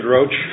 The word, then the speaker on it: Roche: Roche, hard C-H. – Roche, hard C-H. Okay, you may proceed. – Thank you, Your Honor. My name is David Roche.